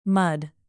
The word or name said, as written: mud